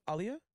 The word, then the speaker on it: Aaliyah: And that's how you say her name, Aaliyah. – Yeah. She anytime she was in an interview, she always let people know that's how you say it.